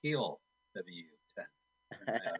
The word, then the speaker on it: Hell W10: Hell, H-E-L-L, space, W10. – It's Heel W10. – That is the German pronunciation, yes. H-E-L-L space W10 1983.